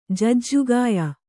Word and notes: ಜಾಜಿಕಾಯ್: jāji kāy

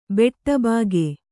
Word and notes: ಬಾಡಬಾಕ: bāḍa bāka

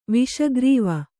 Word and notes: ವಿಷಗ್ರೀವ: Kannada

